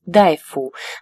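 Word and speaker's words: дайфу (大夫): В эпоху Сун после реорганизации медицинской системы появилась врачебная должность «дайфу», название которой записывалось теми же иероглифами, что и «дафу», но произносилось иначе, в избежание путаницы.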